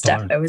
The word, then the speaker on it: depot: It's depot, isn't it? Yeah.